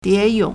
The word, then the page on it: 蝶泳: diéyǒng